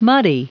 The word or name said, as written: muddy